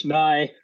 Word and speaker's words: Nigh: It's English. Nigh.